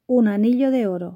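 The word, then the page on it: un anillo de oro: Spanish (Spain)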